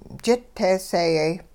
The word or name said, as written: jit teeseeyay